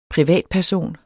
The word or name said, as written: privatperson